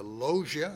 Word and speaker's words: logia: Chiefly because to them were committed the oracles of God, the logia, I believe is how you say it, L-O-G-I-A from the Greek word.